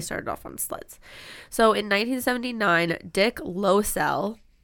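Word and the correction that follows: Loiselle: which I think that's how you say his name. It's Loisell.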